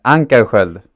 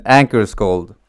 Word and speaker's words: Ankarskold: Ankarskold. – Ankarskold. Am I saying Ankarskold or I can say it in English instead. Ankarskold.